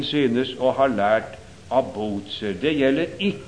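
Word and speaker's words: Bucer: Bozer, som vi altså må uttale ikke med den affekterte latinske uttale som dere lærer til forberedende prøve, hvor man altså vil endelig at vi skal si Kesar og Kikero, men det skal uttales slik som den humanistiske tradisjonen har vært, og da blir det Bozer.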